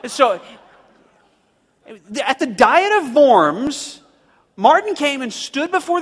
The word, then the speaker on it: Worms: The city in which they invited him to come to this diet was in a city, a German city called Worms. Okay? Now, in German, you pronounce a W with a V. And so actually what they invited him to come participate in was the Diet of Worms.